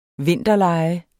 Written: vinterlege

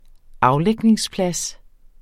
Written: aflægningsplads

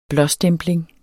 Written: blåstempling

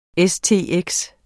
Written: stx

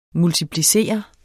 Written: multiplicere

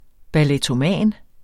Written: ballettoman

